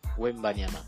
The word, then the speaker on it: Wembanyama: Wembanyama.